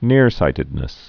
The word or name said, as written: nearsightedness